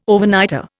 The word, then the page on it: Overnighter: Indian